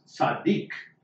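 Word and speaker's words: tzaddik (צדיק): The word for righteousness, if you hear it pronounced in the Hebrew, is tzaddik.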